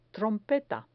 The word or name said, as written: trompeta